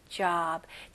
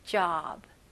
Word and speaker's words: job: job. 진짜 어 발음 여기 오 발음은 진짜 턱을 내리면서 넓게 입을 넓게 해 주셔야 됩니다. job.